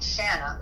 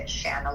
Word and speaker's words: Shana: But my parents are rebels, so they call me Shana, which is neither one of those pronunciations. I don't know. They just thought it sounded pretty, I think. And so they, you know, to let people know how to pronounce my name, is they say Shana like banana.